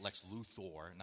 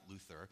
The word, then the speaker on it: Luthor: And let's say one day he's battling Lex Luthor, and that's how you say it, Lex Luthor, not Luthor.